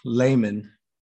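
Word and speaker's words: Lehmann: Yeah, we'll go with Lemon. – Yeah. – Lemon. Love the pronunciation. Lemon in goal. – Exposing myself. Layman.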